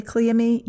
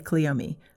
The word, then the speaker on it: cleome: I say cleome, you might say cleome. I say clematis, you might say clematis or clematis. It's all accepted, so you never have to worry about that.